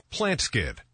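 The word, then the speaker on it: Plant Skydd: Plant Skid. That's spelled plant, S-K-Y-D-D. Plant Skid.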